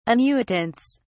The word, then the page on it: annuitants: British English